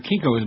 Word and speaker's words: Kinko's: Except for your particular Kinko's, man, which rhymes with Stinko.